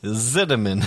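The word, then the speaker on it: Zimmerman: It's with a Z. Zinnemann. – Zinnemann. Oh, God. It sounds like cinnamon. It sounds like... – I could go for some cinnamon rolls, though. – Zinnemann.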